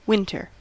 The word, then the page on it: winter: American English